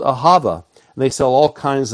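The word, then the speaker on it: ahava (אהבה): The word is ahava. Hebrew is a breathing language. And so a lot of the words, letters, are pronounced with breath. And so ahava is pronounced ahava, ahava.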